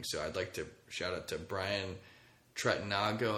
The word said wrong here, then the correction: Tregnago: How do you pronounce it? Brad and Matt do the Inside Missouri Athletics podcast. It's Tregnago. Brad Tregnago.